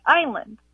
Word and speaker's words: island: why is it island always spelled like island, and why do we always pronounce it like island? – Oh, what a good question. Why does island have an S in it, and why is it pronounced with a long I?